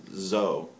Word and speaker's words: Zoe: Actually, her name, Zoe, she pronounced it Zoe, like Joe. Mm-hmm. – Yeah, or like Joey.